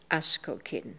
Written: Askokin

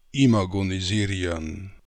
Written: imagonisirian

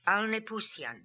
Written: Alnepoosian

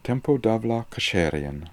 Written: tempo Davlaksherian

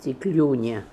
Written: Tiklunia

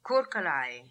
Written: Koorkalai

